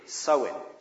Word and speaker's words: Samhain: Well, it goes back to the Celtic feast of Samhain, which is one of those Celtic words that doesn't look at all like it sounds. It's spelled S-A-M-H-A-I-N. And pronounced apparently sowing or something like that.